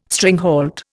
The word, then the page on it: stringhalt: Indian